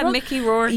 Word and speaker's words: Mickey Rourke: So Mickey Rourke, he was, I don't know if I'm pronouncing that name right. – Mickey Rourke.